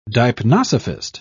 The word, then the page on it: deipnosophist: British English